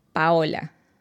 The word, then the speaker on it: Paola: Paola. – Paola. – Yeah. – Paola. – It's with an O. So it's Paola.